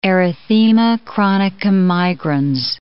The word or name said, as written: erythema chronicum migrans